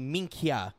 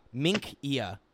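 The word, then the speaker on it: Minchia: Fun Italian pronunciation on this. C-I is pronounced ch, C-H is pronounced ki. So it's actually minkia, minkia. Imagine like that.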